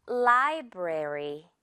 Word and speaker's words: library: 그런데 조금 우리 영어 발음 디테일하게 들어가면 library 이렇게 에에 가까워요. library보다는 rary. Alright? 자, 예문으로 한번 좀 볼게요. 그곳은 공공도서관이야. It's a public library.